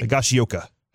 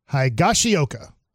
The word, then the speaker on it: Higashioka: Kyle Higashioka. Am I saying that right? – Higashioka. – Higashioka. Okay.